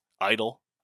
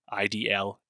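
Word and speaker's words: (G)I-DLE: You don't say the G. It's a series of acronyms and hidden meetings. IDLE, IDLE, silent G, whatever.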